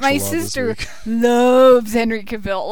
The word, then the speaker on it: Henry Cavill: Henry Cavill.